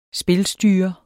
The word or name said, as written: spilstyrer